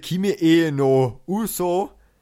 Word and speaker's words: Kimi e no Uso: Kimi e no Uso. Ich hoffe, das spricht man so aus. Ja, spricht man so aus, danke.